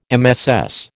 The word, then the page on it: MSS: American English